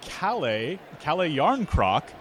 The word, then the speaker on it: Kalle Järnkrok: Kalle Järnkrok, dusting off my Scandinavian pronunciation there.